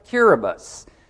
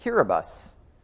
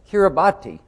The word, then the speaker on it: Kiribati: Now, in that country, when the letters T-I are on the end of the word, it's pronounced S. So listen to how we pronounce that, Kiribati. And when I always pronounce that, they'll go, Kiribati. And I'll say, no, Kiribati, because the T-I is pronounced S.